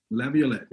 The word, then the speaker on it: Laviolette: Peter Laviolette.